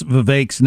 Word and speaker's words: Vivek: But Jack, please learn to pronounce Vivek's name and stick with it. Rhyme it with stick or heck. It doesn't rhyme with cake.